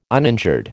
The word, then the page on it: uninjured: American English